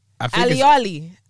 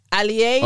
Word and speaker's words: Aleali: I don't know what the emphasis is. Okay, so it's spelled A-L-E-A-L-I. Aliali. Aliali.